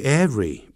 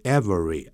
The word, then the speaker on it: every: 顺便提一下 every day 你要分开的哦， every 跟这个 day 要分开，这个 every 不要念成 every 啊， every 这样就可以了，两个音节 every， every day 千万不要连在一起哦，你连在一起这个 every day 它就变成形容词了，分开才是副词放在句尾，你 everyday 连在一起它就变形容词，后头要放名词。